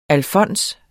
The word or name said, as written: alfons